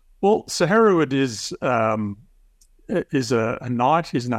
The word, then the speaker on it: Hereward: I will do my best, but Hereward, is that right? – Yeah, Hereward, absolutely, yeah.